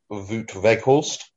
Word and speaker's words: Wout Weghorst: Many of them, it seems, captained Wout Weghorst.